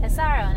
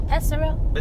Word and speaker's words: Pesaro: So Pesaro, no, Pesaro.